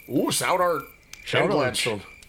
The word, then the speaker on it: South Heart: Yeah, the step grandpa Louie was down there in South Art. That's how you say it. It's not South Art.